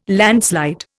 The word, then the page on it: landslide: Indian